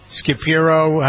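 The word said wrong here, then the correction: Schirripa: how do you pronounce this? I remember having him on the air once with me, and he was a... – You mean Steve Shripa? – Shripa, that's it, yeah.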